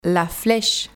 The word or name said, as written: la fleche